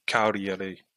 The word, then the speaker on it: Cagliari: Yeah, I've heard presenters pronouncing it Cagliari, like the G is silent.